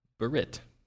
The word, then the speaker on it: berit: And that Hebrew word is spelled B-E-R-I-T, berit, or berit.